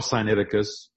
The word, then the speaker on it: Sinaiticus: It's called Sinaiticus. There's a lot of ways to pronounce this. Sinaiticus. Some people say Sinaiticus. Because it was found on Mount Sinai.